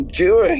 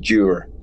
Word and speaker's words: Dewar: I think it's funny, people are finally beginning to understand how to say my name, rather, because I've had so many weird permutations that I see turning up on YouTube, but they finally got the fact that Dewar is Dewar.